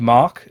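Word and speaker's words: Mack: And on Mac as well, all you Mac users out there. – It's pronounced Mach, and it's a German roller coaster manufacturer.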